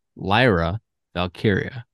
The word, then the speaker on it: Lyra Valkyria: I also want to apologize for mispronouncing Lyra Valkyria's name for pretty much my entire life. Lyra Valkyria.